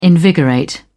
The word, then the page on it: invigorate: British English